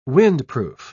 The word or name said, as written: windproof